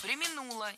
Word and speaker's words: преминула: Потом она так не преминула в воспоминаниях написать, что... – Преминула, неверно. Правильно, преминула. Ударение в этом глаголе падает на второй слог. Причем всегда, во всех формах. Преминуть, преминул, преминул, преминула.